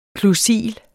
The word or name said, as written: klusil